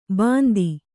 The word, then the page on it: ಬಂದಿ: Kannada